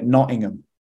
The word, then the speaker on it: Nottingham: Well, if you if you I mean you you got it right, but if you're from Nottingham, you take away the T's, so we call it Nottingham. – Nottingham, yeah, yeah.